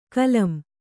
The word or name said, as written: ಕಾಲಂ